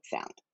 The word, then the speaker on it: Salmon: It's spelled like the fish, but it's Salmon.